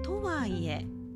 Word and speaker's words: とはいえ: とはいえでこれは頭高です